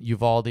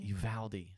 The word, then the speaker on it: Uvalde: Because I think people are, it's not just people in Uvalde. Is that my saying that right? – Uvalde, sorry.